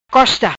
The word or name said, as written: Costa